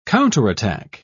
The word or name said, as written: counterattack